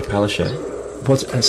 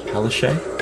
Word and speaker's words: Palaszczuk: And how do you pronounce the name of the Premier of Queensland? – Anastasia Palaszczuk. – Say it again. – Anastasia Palaszczuk. – Palaszczuk. – And you wouldn't perhaps read that as Palaszczuk.